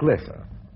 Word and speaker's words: Loesser: Which brings me to the part writer of this song, one Frank Lesser. The name is spelled L-O-E-S-S-E-R, but is pronounced Lesser.